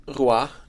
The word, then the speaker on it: Roy: Kevin Roy, det är så det ska uttalas, har vi förstått?